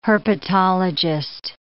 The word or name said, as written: herpetologist